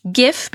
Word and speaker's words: GIF: So if you're like me, and I am saying GIF, by the way, the creator of the GIF says it's pronounced GIF. My son would argue that it should be pronounced GIF because it stands for graphical. Graphical something format, but anyway, I say GIF.